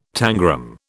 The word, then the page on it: tangram: British English